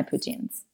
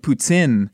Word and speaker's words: poutine: Because I had no idea that in Quebec, they pronounce it very hard, like poutine. – Yes. – Poutine. – And then in an American-born French speaker says poutine pretty hard.